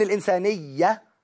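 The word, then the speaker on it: الإنسانية: بس احنا اشترطنا ان الانسانيه خلي بالك من التكه دي اللي ايه ياء مشدده. يعني ياء مشدده.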